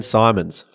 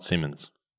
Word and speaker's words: Simons: Number two is Dan Simons from Golf Australia, people and culture manager. – Not Simmons. – Not Simmons. If you go back and listen to the episode, Ryan mispronounces his name.